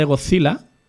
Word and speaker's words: Gojira: Pero claro, él siempre que habla de Godzilla, lo pronuncia como se pronuncia en japonés. Gojira.